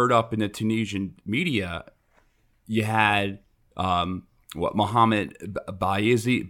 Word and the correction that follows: Bouazizi: Bazizi, I can't pronounce his last name, Bawazi. – Bouazizi? – Bouazizi.